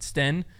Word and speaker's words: Steen: So it looks like it's pronounced Steen. Charlie Eisenhood pronounced it Sten all weekend. So I feel like he would know more than me.